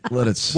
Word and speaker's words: Lititz: Well, that's how you spell L-I-T-I-T-Z. – You sound like a Frenchman. – L-I-T-I-T-Z, Lititz.